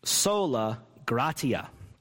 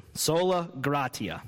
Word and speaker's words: gratia: sola gratia, or some people say sola gratia, just depends on how you learned to pronounce Latin, Both are fine. Latin's a dead language. Pronounce it how you want. I kind of like gratia, but we'll go with gratia because it's what most of us are used to. Sola gratia. That's the Italian way of saying it.